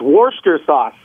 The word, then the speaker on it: Worcestershire: It's Worcester sauce. – Worcester sauce? – Worcester sauce. – 150%. Moi, je suis un bloke. – Mais c'est Worcester sauce? – Non, le Worcester sauce.